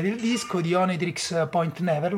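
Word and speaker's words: Oneohtrix Point Never: l'ho detto bene? Sì, so sì. che molta gente non... Cioè, ho letto, ma come cazzo si dice, come si chiama? – Come... Onitrix Point Never. – Onitrix, io lo leggo come... cioè lo pronuncio come si legge.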